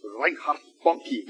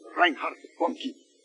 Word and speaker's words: Reinhard Bonnke: The evangelist Reinhard Bonnke, you ever heard of him? I just say his name like that because that's how he says it. It's said again. Reinhard Bonnke.